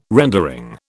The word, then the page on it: rendering: British English